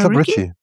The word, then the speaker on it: Ricci: Ja, Christina Ricci.